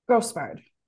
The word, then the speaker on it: Grosbard: Grosbard.